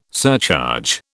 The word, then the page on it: surcharge: British English